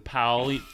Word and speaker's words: Pauli: Anyway, the significance of this thing, it's called POP. Oh, gosh. I can't pronounce things. The Pauli net, it's called, because the Pauli exclusion principle basically says that two electrons can't exist in the same state within an atom.